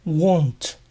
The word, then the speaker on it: won't: Para recordarlo, les aconsejo que recuerden que es parecido a don't, en donde la D se reemplaza por una W, won't. La T final no se suele pronunciar cuando won't le sigue una palabra.